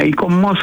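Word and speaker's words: ICOMOS: Αν δεν το γνωρίζετε, αυτό ακριβώς κάνει το ΙΚΟΜΟΣ ή το, δεν ξέρω αν πρέπει να το πω, ΑΙΚΟΜΟΣ. Πρώτα απ' όλα καλησπέρα σας. – Καλησπέρα σας. Ένα ΙΚΟΜΟΣ θα το λέγαμε, γιατί έτσι προφέρουν όλο με τον γαλλικό τρόπο.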